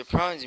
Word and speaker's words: prends: So prawns means I take. It's spelled P-R-E-N-D-S.